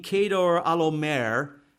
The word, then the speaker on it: Chedorlaomer: And the key king here is King Kedor Alomer, is how you pronounce that, but it looks like Cheddar Lamer.